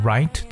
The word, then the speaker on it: rite: Oh, 另外就是《礼记》，那《礼记》呢叫 Book of Rights。We're gonna learn one word called right. That's R-I-T-E. Right 这个词的发音跟 R-I-G-H-T 的发音是一样，but that's totally two different words.